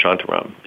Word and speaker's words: Shantaram: And by the way, it's pronounced Shantaram.